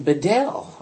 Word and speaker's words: Bedell: But these people who were getting the Bible published, and who, like Bishop Usher up Armagh, and some of these guys, and Bedell or Beadle, however you want to pronounce his name, these guys were under the radar.